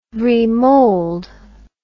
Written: remold

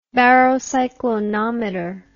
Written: barocyclonometer

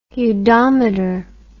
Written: udometer

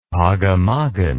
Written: pogamoggan

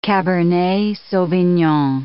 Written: cabernet sauvignon

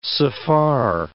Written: Safar

